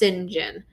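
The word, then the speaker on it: St. John: Also, let's talk about how the guy who she was the missionary man, Saint John. That's how it's spelled, right? You know how it's pronounced? Sinjin. What type of thing were they on in the Victorian era or whatever to pronounce it Sinjin?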